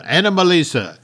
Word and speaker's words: Anomalisa: Wonderful Pete Docter film, but we had when Marnie was there, the Shaun the Sheep movie, A Boy in the World, and Anomazle. Am I saying that name wrong? I'm saying that name wrong. Anomalisa.